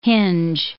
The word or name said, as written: hinge